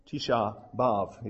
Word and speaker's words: Tisha B'Av: The 9th of their month of Ab, Tisha B'Av is how they pronounce it.